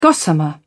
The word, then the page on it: gossamer: British English